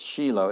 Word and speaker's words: Shiloh: It's actually Shiloh is probably how you're pronouncing it from your Bibles, but it's Shiloh.